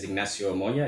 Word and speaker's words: Ignacio Moya: So as you rightly pronounce my name very well, my name is Ignacio Moya. It's a Spanish name, by the way.